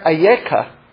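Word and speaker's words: Ayeka (איכה): That's how you spell Eicha. It's pronounced there Ayeka, but it's the exact same word.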